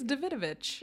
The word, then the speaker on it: Davidovich: Okay, we got Chad Michael Murray, Grant Cho, Lolita Davidovich. – I think it's Davidovich. – It is.